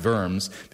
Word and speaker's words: Worms: at the Diet of Worms, spelled W-O-R-M-S, which is a weird way to think about things in English, but it's pronounced Worms, because otherwise it's the Diet of Worms, and that does not sound like a good diet, okay?